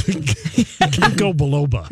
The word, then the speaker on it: ginkgo biloba: It's ginkgo biloba.